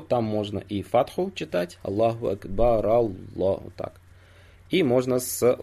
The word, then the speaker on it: Аллаху акбар: То есть произносить ра, первое ра, вот акбар в конце, да, произносить с фатхой. Аллаху акбар, Аллаху так. Вот. Но тоже Ибн Хаджар, рахимахуллах, пишет, что часть ученых сказала, что лучше с даммой.